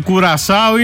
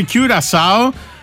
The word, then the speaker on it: Κιουρασάο: Μανιάνα και θα επιμείνω και σε σένα, δεν υπάρχει Κουρασάο, είναι Κιουρασάο. Λέγεται το ρημάδι το νησί.